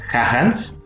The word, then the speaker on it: Jahannes: Esta frase la dijo un pastor bautista de Estados Unidos que se pronuncia Hahans se escribe J-A-H-A-N-N-E-S.